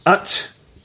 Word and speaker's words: Utqiagvik: The sun set on the town of, oh my God, Utqiagvik. – That's exactly how it's pronounced.